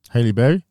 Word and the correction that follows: Halle Berry: Halle Berry.